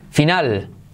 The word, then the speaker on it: final: Number 93, final. Se escribe igual y se dice final. Final, fíjate la L al final de palabra como casi no se pronuncia.